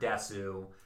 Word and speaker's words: desu: It's a early meme of the word Desu. And Desu is... Well, apparently it's just des. It's actually, now I'm looking up here, the U is not pronounced.